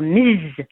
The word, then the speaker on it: Ms.: Like any time I go to do anything online, you get this drop-down menu, Mrs., Miss, or Ms., you know, with the pronouncing the Z.